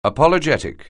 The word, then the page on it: apologetic: anglais